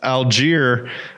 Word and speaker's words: Allgeier: Our guy, Tyler Allgeier, even though it was actually pronounced Algier, comes in.